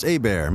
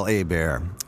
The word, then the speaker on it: Hebert: I'm from Louisiana, so my last name is pronounced Hebert. My name's Kyle Hebert, and I am best known as the narrator from Dragon Ball Z.